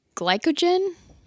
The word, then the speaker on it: glycogen: So within the first 6 to 72 hours of not eating, all your glycogen, am I pronouncing that right? – Sounds right.